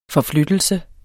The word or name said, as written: forflyttelse